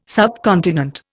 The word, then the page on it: subcontinent: Indian